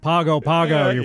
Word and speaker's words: Pago Pago: It's pronounced Pago Pago, fools.